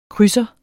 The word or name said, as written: krydser